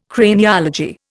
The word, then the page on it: Craniology: Indian